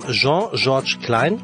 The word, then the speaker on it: Jean-Georges Klein: Mit nur 17 Jahren nahm Jean-Georges Klein, spricht man so aus? – Ja, genau so.